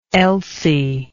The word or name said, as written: elsie